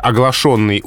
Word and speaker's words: оглашенный: Вот смотрите, во-первых, по поводу произношения. Можно кричать как оглашенный. Например, оглашенный утром приказ, но кричать как оглашенный.